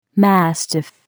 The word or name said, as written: mastiff